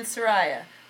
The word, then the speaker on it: Soraia: And we're from the band Soraya.